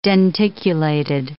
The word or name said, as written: denticulated